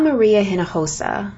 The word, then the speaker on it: Hinojosa: I'm Maria Hinojosa, pronounced in Spanish, of course.